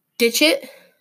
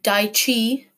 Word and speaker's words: Dixit: Like I said, it's spelled D-I-X-I-T. I'm going to call it Dixit, which is one of the pronunciations on the interwebs. In another pronunciation is Dixit or Ditchit or Dichi. There's all sorts of different stuff. You can call it whatever you want.